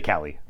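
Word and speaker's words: Kallie: It's K-A-L-L-I-E-P-L-A-G-G. – K-P. Yeah, so. – You just say Kali.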